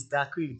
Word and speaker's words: Dakraam: I'll say, no, it's Dark Cream, D-A-K-R-A-A-M, Dark Cream.